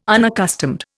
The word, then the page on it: unaccustomed: Indian